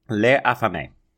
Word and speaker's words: Les Affamés: Is it Les Affamés, then? Is that how you say it? – Les Affamés.